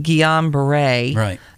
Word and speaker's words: Guillain-Barré: I don't know how people pronounce it. We looked it up online. They pronounced it Guillain-Barré syndrome.